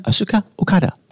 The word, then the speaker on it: Asuka Okada: Asuka Okada.